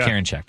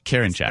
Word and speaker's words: Karinchak: It's James Karinchak, by the way. – Yeah, Karinchak.